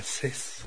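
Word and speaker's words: Zaz: Cés, se escribe Z-A-Z, y en francés se pronuncia Cés, que su pasión por la música viene desde muy pequeña.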